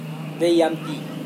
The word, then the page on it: veyampi: Serewen